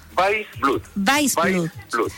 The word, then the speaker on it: Weissblut: Mario, bueno, ¿cómo se pronuncia tu apellido primero que todo? ¿Weissblut? – Weissblut, tal como perfecto.